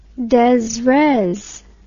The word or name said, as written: desres